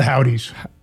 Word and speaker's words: Houthis: The Houthis. – The Houthi? That's what they say, the Houthis? – Houthis, I believe that's right.